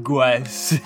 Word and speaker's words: Gauss: It's called Gauss or Goose. However you're supposed to pronounce that. – Gauss.